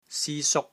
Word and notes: SI1 SUK1: Cantonese

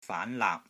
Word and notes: FAAN LAAP: FAAN2 LAAP3

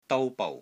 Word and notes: DOU1 BOU6: Cantonese

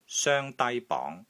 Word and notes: SOENG1 DAI1 BONG2: Cantonese

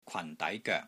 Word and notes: KWAN4 DAI2 GOEK3: Cantonese

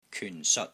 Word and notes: KYUN4 SEOT6: Cantonese

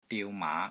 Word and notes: DIU3 MAA5: Cantonese

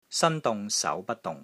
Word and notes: SAN1 DUNG6 SAU2 BUT1 DUNG6: Cantonese

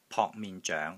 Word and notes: POK3 MIN6 ZOENG2: Cantonese

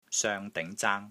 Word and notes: SOENG5 DING2 ZAANG1: Cantonese

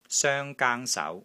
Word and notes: SOENG1 GAANG1 SAU2: Cantonese

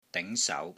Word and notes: DING2 SAU2: Cantonese

